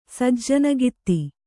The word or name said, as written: ಸಜ್ಜನಗಿತ್ತಿ